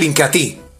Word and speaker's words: Incatea: Entonces el Incatí, se escribe Incatea, pero se pronuncia Incatí, en una forma natural, me ayuda para cuando hay impotencia o hay eyaculación precoz.